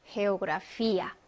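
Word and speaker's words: geografía: 그 다음에 역시 해가 에와 만났습니다. 그러면 헤오그라피아 해서 강한 히읗 발음이 나게 되죠.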